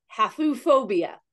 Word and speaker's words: Haphephobia: It's H-A-P-H. – H-A-P-H? – Uh-huh. – Okay. – E-phobia. P-H-O-B-I-A. So it has a pronunciation, but it's Haphephobia.